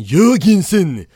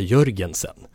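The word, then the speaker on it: Jørgensen: Nu Danmark, säger han. Martin Jörgensen! Men det behöver vi inte säga, för det har blivit så pass etablerat i Sverige att säga Jörgensen.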